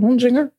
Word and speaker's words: Honsinger: Clara Honsinger. Dan ga ik het vast verkeerd uitspreken. Honsinger, ja. Okay. – Helemaal goed, jongen.